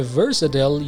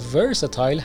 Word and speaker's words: versatile: برای قسمت بعدی یک کلمه رو براتون انتخاب کردم، کلمه versatile یا versatile. هر دو تلفظ درست هستن و هر کدوم که شنیدید تعجب نکنید یا متوجه بشید که در واقع اینا یکی هستن.